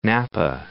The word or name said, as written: Napa